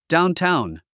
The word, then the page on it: downtown: dáuntáun; ダウンタァウン